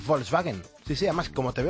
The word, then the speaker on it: Volkswagen: Y El País: Volkswagen admite fraude en 11 millones de coches. – Estás pronunciando muy bien Volkswagen, porque a mí en el colegio me lo hicieron repetir 30.000 veces.